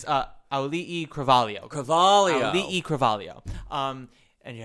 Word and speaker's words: Auli'i: Auli'i.